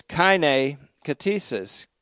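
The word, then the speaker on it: kaine katesis (καινὴ κτίσις): Sixth, there's the kaine katesis, K-A-I-N-E. You'll notice I'm just using transliteration now into the English uncials. But it's kaine katesis, K-A-I-N, long E.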